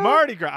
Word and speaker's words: Mardi Gras: This movie's based around Mardi Gras. Am I saying that right? – You are now. – Oh boy. – Mardi Gras? – Mardi Gras. I think I missed the R the first time.